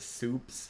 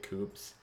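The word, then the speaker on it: Cewpz: Usually people have pronounced it Soups for whatever reason. I love Soups. – I can definitely see Soups. Soups. With how it's spelled, I can see how it's... – I'm glad, honestly. Soups. If you want to say Soups, I'm okay with that. C-E-W-P-Z. I pronounce it Coops.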